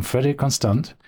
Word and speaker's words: Frederique Constant: We have with us Niels Egerding from Frederick Constant.